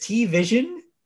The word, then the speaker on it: T-Vision: So the carrier announced a new suite of TV, or how do you say this? T-Vision? – Yes, it's like T-Mobile.